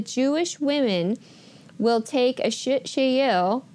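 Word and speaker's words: shit shayil (אשת חיל): That actually virtuous woman comes from the Hebrew word, and I practice this a lot, and I'm going to try to say it right. It's shit shayil, okay?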